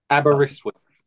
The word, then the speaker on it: Aberystwyth: It's Aberystwyth. – Aberystwyth.